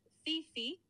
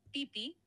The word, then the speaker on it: Phi Phi: Seguro te referís a las islas Fifi, que en realidad se pronuncia más como Pipi, sin marcar la F.